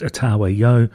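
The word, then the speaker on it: Otava Yo: There's another more Russian way of spelling it, but apparently it's pronounced Otawa Yo.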